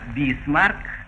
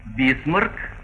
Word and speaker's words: Бисмарк: Так, «бисмарк» — это произношение по-иностранному, а по-русски «бисмарк» как «насморк».